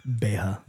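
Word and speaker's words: beatha: Ushka Betha. – Aye. – Oof. Yeah. So, I don't want to correct your pronunciation, but that was wrong. – What is it? – Beha. – Beha, so it is, yeah.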